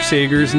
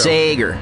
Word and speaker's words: Sager: Listen, we haven't been pronouncing Carol Bayer Sager's name correctly all night. Let's stop. We're halfway through the show. – Sager. – There you go.